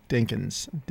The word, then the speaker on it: Dinkins: Dinkins, Duncans, Dunkins. I mean, it's crazy. Nobody can pronounce my name. Dinkins. Dink. Think dink, like stink, and then ends, dink ends.